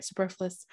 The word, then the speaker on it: superfluous: And did I say that right? Superfluous. – Superfluous. Yeah.